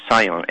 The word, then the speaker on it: scion: It's also called a Sion, S-C-I-O-N, pronounced exactly like Zion.